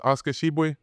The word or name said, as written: Oscar Tshiebwe